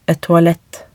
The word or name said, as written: et toalett